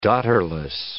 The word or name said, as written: daughterless